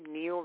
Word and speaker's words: Neil: Just look me up under my name, Neil Riebe. My name is spelled N-E-I-L, and then last name, R-I-E-B as in boy, E.